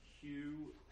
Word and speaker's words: Huw: Yeah, my full name is Michael Hugh Padgett, but my middle name is spelt H-U-W, not H-U-G-H.